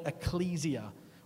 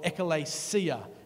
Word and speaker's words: ekklesia: they all come from a Greek word called ecclesia or ekklesia, was how they would pronounce it, uh, which is the word found in the New Testament for, you guessed it, church.